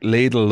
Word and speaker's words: Lidl: See what you said, Lidl. Did they tell you that you had to say it this way? Or do you? – Yeah. It's like Lidl, like needle. Lidl.